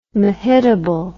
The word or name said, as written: mehitabel